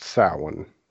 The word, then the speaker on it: Samhain: And that may not sound familiar, but it's the spelling of it is nowhere near how you pronounce it. The spelling is Samhain, which I'm sure you've seen that before. – Yeah. – But it's pronounced Samhain.